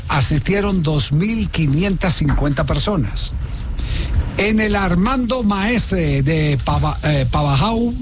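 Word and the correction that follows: Pavajeau: Sí, se pronuncia Pabayó.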